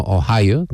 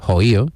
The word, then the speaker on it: Ohio: Tengo un amigo que es de Cádiz, mi amigo Antonio Hernández Rodicio, y él pronuncia no Ohio, dice Ohio, con lo cual tiene mucho más punto,